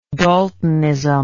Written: daltonic

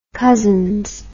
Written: cousins

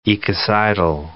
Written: ecocidal

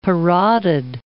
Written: parotid

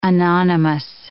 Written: anonymous

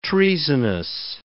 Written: treasonous

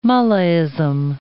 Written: mullahism

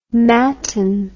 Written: mattin

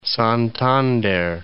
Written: Santander

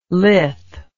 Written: lith

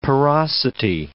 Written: porosity

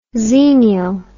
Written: xenial

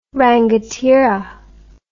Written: rangatira

